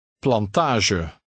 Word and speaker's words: plantage: Try number four, using the same French sound for G. The final E is pronounced. – Plantage.